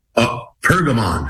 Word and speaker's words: Pergamon: It's called The Altar of, is it Perigmon? Perigmon? How do you say it? – Pergamon. – Pergamon. Pergamon. The Altar of Pergamon.